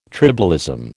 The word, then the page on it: tribalism: American English